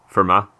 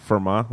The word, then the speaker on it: Fermat: So it rhymes like with Kermit. – How would you say it? – Fermat. – Oh, Fermat. That makes more sense.